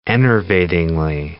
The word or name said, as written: enervatingly